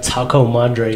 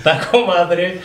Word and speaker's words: Taco Madre: Taco Madre is the way you say it, and I would appreciate it. – Do I say Taco Madre? – Taco Madre.